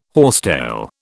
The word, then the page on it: horsetail: British English